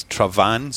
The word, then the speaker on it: Traigh Bhan: So very difficult to spell, very difficult to pronounce, but it's Travan. So like T-R-A and then Van, V-A-N. So B-H makes a V noise.